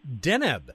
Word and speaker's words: Deneb: Deneb. At least that's how you told me it's pronounced.